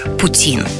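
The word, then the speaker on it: poutine: Ну, еще ударение на французский манер – Путин.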